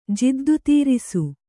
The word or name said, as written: ಜಿದ್ದುತೀರಿಸು